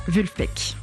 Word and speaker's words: Vulfpeck: Toujours est-il que le nom du groupe serait la prononciation à l'allemande, donc, de ce groupe imaginaire nommé Wolfpack, soit meute de loups en français, transformé donc en Vulfpeck.